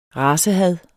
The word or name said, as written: racehad